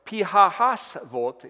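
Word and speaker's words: Pi-hahiroth: Now the Lord spoke to Moses saying, speak to the children of Israel that they turn and camp before Pihahasvot is the way it's pronounced, between Migdol, the sea, opposite Baal Zephon, and you shall come before it by the sea.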